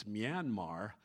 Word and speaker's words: Myanmar: All right, greetings to those that are watching from Myanmar. Most of us, it's not Miramar in California, it's Myanmar. At least that's how we pronounce it, and it's in Indonesia, and it's a totally different place than Miramar, California, where Top Gun training takes place.